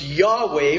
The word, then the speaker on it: Yahweh: L-O-R-D, it is their way of designating that what is actually there, probably best pronounced Yahweh from the Hebrew, is the personal name of God, Yahweh.